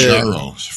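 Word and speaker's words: Mounjaro: What are the Manjaro from Mount Kilimanjaro? – Okay.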